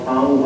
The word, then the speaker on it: Tonte: My name is Ronald Tong. Last name is spelled T-O-N-T-E.